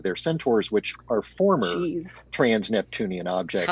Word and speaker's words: trans-Neptunian: And why were you so surprised about the trans Neptunian? Is that the right word? Am I saying it right? – That's correct. Yeah.